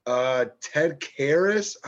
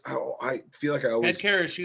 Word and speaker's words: Ted Karras: But Ted Karras, Ted Karras, you got it.